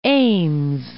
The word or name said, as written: Ames